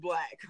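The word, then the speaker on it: 6LACK: I literally saw, I follow him on Instagram, and I swear I saw him post something that was like, it's pronounced Black. Like, it literally said it's pronounced Black. – I saw it on Google that it was pronounced Black. – Oh my goodness, that man named Six Lack.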